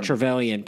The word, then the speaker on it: Trevelyan: did I say that right? Trevelyan? I don't know. – Trevelyan, played by Sean Bean.